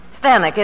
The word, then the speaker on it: Stanwyck: Well, that's how they said Stanwyck in England. – Oh. – Stanwyck, it rhymes with Zanuck.